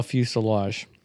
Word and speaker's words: fuselage: And I remember you were quizzing me while I was like standing, and I could not spell fuselage. – It's a weird word. – Yeah, and that includes... something that's pronounced like lodge.